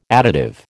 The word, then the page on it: additive: American English